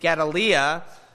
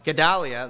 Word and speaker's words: Gedaliah: Jeremiah 40 talks about the king of Babylon had appointed Gedaliah. I probably mispronounced that. Gedaliah, there we go.